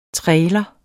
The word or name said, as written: trailer